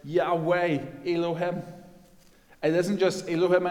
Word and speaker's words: Yahweh: And this word Yahweh is the four-letter word YHWH, pronounced Yahweh in English.